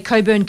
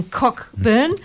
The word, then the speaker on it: Cockburn: And he was talking about the upcoming NPL WA Round 12 fixtures, and he couldn't say Coburn correctly. He was saying Cockburn. And I was just laughing myself. Only us here in Perth can say it correctly, Tommy.